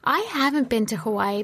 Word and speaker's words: Hawaii: So mm I flew to the Big Island or the island of Hawaii or Hawaii as it's actually properly pronounced.